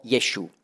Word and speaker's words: Jeschu: Man schreibt dann Hebräisch J, Shin für Sch und ein V für U. Das wird dann ausgesprochen Jeschu.